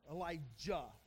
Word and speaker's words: Elijah: Elijah, okay, make sure you get those pronunciations right, because Elisha is different from Elijah.